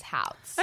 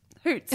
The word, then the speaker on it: Houts: Do we think it's Houts? H-O-U-T-S? – I think H-O-U-T-S is Houts. – Okay. Brooke Houts is a 20-year... Hoots. – No, I'm kidding, it's Houts. – Brooke Houts